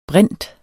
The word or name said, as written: brint